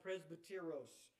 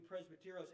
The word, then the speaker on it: presbyteros: The first one, and I'll spell it for you if you want me to, it's called presbyteros. Sounds familiar, like the word, y'all heard the word Presbyterian? That's the root word of that would be, in this, the Greek would be presbyteros.